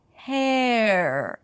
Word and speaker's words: hair: You don't wanna pronounce the R before you hear the pure vowel, the A vowel, hey. Only then you bring the tongue up for the R, hair, and round your lips just a bit, hair, her.